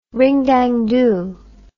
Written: ring-dang-do

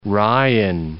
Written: Ryan

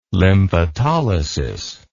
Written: lymphatolysis

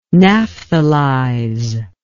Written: naphthalize

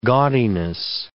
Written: gaudiness

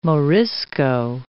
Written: Morisco

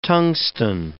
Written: tungsten

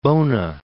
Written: Bona, Mount

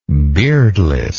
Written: beardlessness

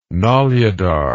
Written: Noludar

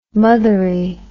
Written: mothery

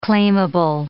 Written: claimable

